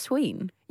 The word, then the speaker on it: Samhain: S-A-M-H-A-I-N. – And you think it's pronounced Sween? – Yeah, because it was like Celtic.